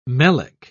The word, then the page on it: melic: British English